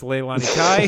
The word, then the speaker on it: Leilani Kai: Alundra Blaze versus, I believe you butchered her name, but it's Leilani Kai at WrestleMania 10.